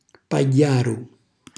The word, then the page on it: pagghjaru: calabrese